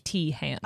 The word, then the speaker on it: Teahan: His last name is spelled T-E-A-H-A-N. So literally like Tehan. – Okay, cool. – So he is a licensed therapist. I don't know what L-I-C-S-W means, but that's the letters behind his name.